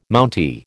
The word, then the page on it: Mountie: American English